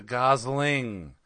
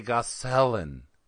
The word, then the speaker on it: Gosling: He's a Gosling, not a Gosselin.